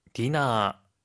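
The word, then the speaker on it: 디나 (ディナー): 디너, 만찬입니다. – 세토, 세토. – 세토, 세트란 뜻인데요. 혹시 발음 팁 있을까요? – 하이, 몸에 주의해야 하는데요. 런치의 러 부분은 라, 디너의 너 부분은 나로 발음합니다.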